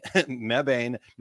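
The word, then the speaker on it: Mebane: I'm not saying it right. It's M-E-B-A-N-E, Membane, North Carolina Distribution Center by June 30th.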